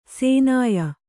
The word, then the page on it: ಸೇನಾಯ: sēnāya